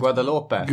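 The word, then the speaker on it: Guadeloupe: Guadalupe.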